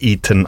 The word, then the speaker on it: Eaton: Du bist ein anderer Dominik Eaton als der Dominik Eaton, den wir haben. – Genau, mein Nachname schreibt man auf englischer Art, also E-A-T-O-N.